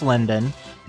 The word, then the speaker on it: Lyndon: And I am under the name Chris Lyndon. That's spelled L-Y-N-D-O-N, as in Lyndon Johnson.